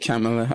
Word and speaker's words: Kamala: Whereas, like, I read an article saying that it's pronounced the same as Pamela, just with a K. – Kamala, okay.